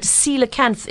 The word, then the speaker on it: coelacanth: Okay, well, it might be pronounced that way if you were reading it phonetically, but coelacanth is the correct pronunciation.